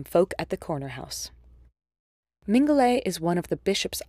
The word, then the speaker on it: Mingulay: It is strange to call such a tiny island in the Outer Hebrides Great Island, so it's understandable why some people would think that Mingulay actually comes from Minchgala. There are a few folk singers who pronounce it that way in their renditions of the song, but the etymology doesn't line up.